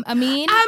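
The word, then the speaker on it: Aminé: Amine! Is it pronounced Yo, yes. I think he's got the chickle over his E.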